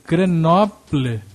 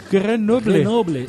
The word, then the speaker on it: Grenoble: Como é que se pronuncia isso? Grenoble. Mas eles botam o acento final. – Eu não sei. – Grenoble? – Não sei.